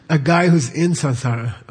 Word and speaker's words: samsara: Mispronounced samsara, okay? You can't have an M in Sanskrit before an S, okay? Never. Okay, it's samsara, like a French N, samsara.